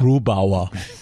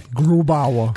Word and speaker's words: Grubauer: Grubauer. – You have to say it like that. I'm sorry. – Grubauer. Good.